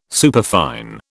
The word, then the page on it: superfine: British English